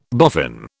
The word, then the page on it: boffin: British English